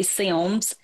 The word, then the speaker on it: Sims: If you're from there, you would say Sims with two syllables.